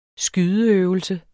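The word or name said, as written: skydeøvelse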